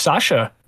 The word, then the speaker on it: Sascha: I am Too Much Sasha.